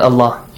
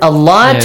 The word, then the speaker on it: Allah: This affects the word Allah to be pronounced with an A sound.